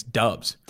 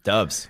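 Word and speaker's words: Doubs: I found out you might have to move up our boy Romeo up the board because I found out how to pronounce his last name. Guess it's Dubs. – Dubs.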